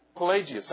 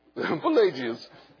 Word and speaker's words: Pelagius: Pelagius. How do we say his name? – In British? – Pelagius. Because Pelagius was from England, right?